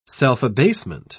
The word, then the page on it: self-abasement: British English